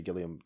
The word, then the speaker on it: Gilliam: It's definitely got some Terry Gilliam. – Yeah. – Am I saying that right? Or is it Gilliam? Have I just butchered? – No, you got it right the first time.